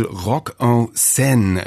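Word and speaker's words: Rock en Seine: Dort findet seit 2003 Ende August immer das Festival Rock en Sen statt.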